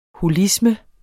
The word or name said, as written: holisme